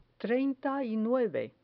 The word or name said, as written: treinta y nueve